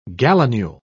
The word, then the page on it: gallinule: British English